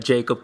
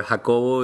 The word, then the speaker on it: Jacob: My name is Jacob Koenig, or Jacobo if you're daring to pronounce it in Spanish, but you can call me Jacob.